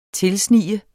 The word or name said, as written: tilsnige